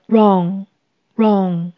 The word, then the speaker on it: wrong: 请留意 W 是不发音的。 Wrong，wrong。